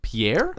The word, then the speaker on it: Pierre: Actually, how do you say the capital of South Dakota? Pierre? – Yeah. – Is that right? – Mm-hmm.